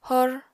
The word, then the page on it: hor: apatani